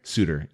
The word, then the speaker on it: Souter: Souter, yeah.